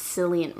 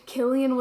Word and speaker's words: Cillian: For the longest time, I thought his name was pronounced Cillian Murphy. Isn't that embarrassing? – That's embarrassing. – Yeah. And he's our cousin, and I was saying it wrong.